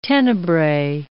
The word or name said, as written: Tenebrae